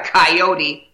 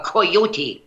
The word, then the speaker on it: coyote: Oh, I noticed the correct Spanish pronunciation. Not a coyote, a coyote.